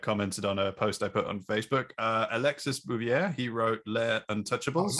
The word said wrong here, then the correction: Alexis: Is it Alexi or Alexis? – Alexi.